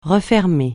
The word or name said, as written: refermer